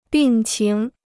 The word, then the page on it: 病情: bìng qíng